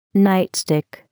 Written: nightstick

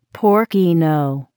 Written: porcino

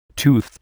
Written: tooth

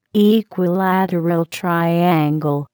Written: equilateral triangle

